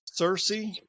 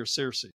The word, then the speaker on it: Searcy: So Gannon and Isaiah both go to Harding University in Searcy. Am I saying it right? Searcy or Searcy? Searcy, right? Okay. Yeah, right. He always beats me up when I say it wrong.